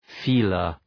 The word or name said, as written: feeler